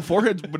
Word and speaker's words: forehead: Forehead would be hard to rhyme. – Have you seen the pictures? – The problem is forehead doesn't fit iambic pentameter because the accent's on the first syllable.